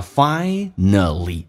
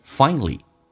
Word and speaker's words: finally: 来看一下 finally 这个单词，英式发音叫 finally，美语当中跟 l 我刚刚有讲过被 l 所吸收，就变成 finally.